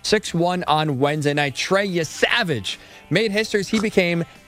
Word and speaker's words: Yesavage: I buy Yesavage as a pronunciation.